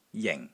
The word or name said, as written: JING4